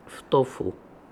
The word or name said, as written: Ftofoo